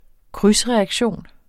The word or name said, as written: krydsreaktion